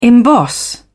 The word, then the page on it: emboss: British English